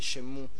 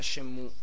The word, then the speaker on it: Shenmue: E un altro dei grandi problemi che ci saranno questa sera sarà la pronuncia di Shenmue, che ci conviene mettere, cercare di capire subito all'inizio, prima di dire qualche oscenità. Io penso si dica Shenmue. – Così. Io ho sempre detto Shenmue.